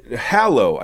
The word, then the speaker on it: hallow: So to hollow, hallow, I got to say it right, not hollow.